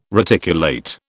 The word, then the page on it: reticulate: British English